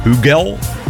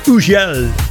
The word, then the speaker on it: Hugel: Sommigen spreken het uit als Hugel. Het is een Fransman. En eigenlijk moet je dan de H ook niet eens uitspreken. Hugel.